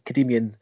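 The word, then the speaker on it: Kadimyan: Yes, I said that right. No pressure that. So we think so. – Well, I did do a Google check before we came on, so I think that's Kadimian is the way to pronounce that.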